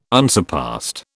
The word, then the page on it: unsurpassed: British English